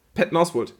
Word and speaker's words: Patton Oswalt: Ich weiß gar nicht, wie spricht man denn aus? Patton Oswalt? – Patton Oswalt, ja.